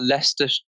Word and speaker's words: Leicester: It's like, it's like we've got Leicester, Leicester in the UK. – Yeah. I don't know if you've seen it, but it's spelled Leicestershire. Shire. Yes. It looks nothing like the, how the, how it's pronounced and yeah.